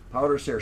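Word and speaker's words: pwdre ser: P-W-D-R-E. Powder Sare, star jelly, or angel hair.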